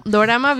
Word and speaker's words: dorama: Bueno, dorama viene de drama, pero en, en Japón se pronuncia dorama, porque tienen esta...